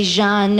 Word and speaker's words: Jean: Thus, Arthur, pronounce Jean, pronounce the French way, Jean, no more, no more head.